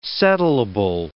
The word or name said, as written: settleable